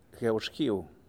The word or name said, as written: GueuXguiu